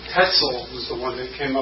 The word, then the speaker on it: Tetzel: It was Tetzel. – Oh, Tetzel. – Yeah. That rhymes with pretzel. – Pretzel. – Tetzel and pretzel.